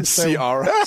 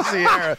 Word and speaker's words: Ciara: Sierra. Is that her name? – Yeah, Sierra.